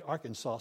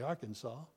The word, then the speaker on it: Arkansas: You don't say Arkansas, do you? You say Arkansas.